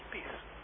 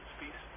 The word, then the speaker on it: Spies: En dan spreek je dat Spies. Kom op zijn Nederlands. Spies. En alleen dubbel S aan het eind.